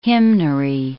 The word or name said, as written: hymnary